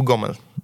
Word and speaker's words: Homel: Po białorusku mówimy Homel, po rosyjsku Gomel.